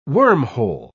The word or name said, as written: wormhole